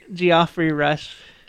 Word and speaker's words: Geoffrey Rush: Geoffrey Rush, I think is how it's pronounced.